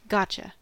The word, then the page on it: piège: France; [pjɛʒ]